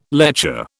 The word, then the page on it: lecher: British English